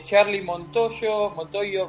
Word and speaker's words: Charlie Montoyo: Por parte de Charlie Montoyo, mejor dicho, así es como se pronuncia, es un ex entrenador de banca de los Tampa Bay Rays.